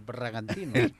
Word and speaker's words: Bragantino: El Bragantino. – Sí, así es, así se pronuncia.